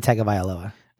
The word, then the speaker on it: Tagovailoa: My backup quarterback is Tua, Tagovailoa. I always have a hard time pronouncing his name. Tagovailoa? – Yeah, basically. – Okay. – Tua Tagovailoa.